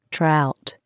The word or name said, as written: trout